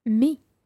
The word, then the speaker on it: mis: Me. – And that's spelled M-I-S. Me. – Me. – Okay, and of course the S is silent.